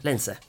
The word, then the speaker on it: Leijnse: Hej Martin Leijnse, välkommen till Forskaren och jag. – Tack så mycket. – Uttalar jag ditt efternamn rätt? – Ja, det var helt okej. – Bra, Leijnse. Bra.